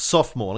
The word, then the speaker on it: sophomore: Yeah, she kept telling me it's sophomore. And I go, well, it's not. It's sophomore. You may choose to mispronounce it if you choose.